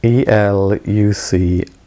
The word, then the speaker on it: Elucidation: Elucidation, elucidation. Pronounced elucidation from the French, because it's an old French text.